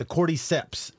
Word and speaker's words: cordyceps: What do we already know about the cordyceps, if I am pronouncing that correctly? – I think you are.